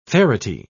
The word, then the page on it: ferity: British English